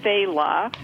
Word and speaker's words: Failla: I could drink them all day from Fayla, which is hard to pronounce. – F-A-I-L-L-A? – Yes, F-A-I-L-L-A.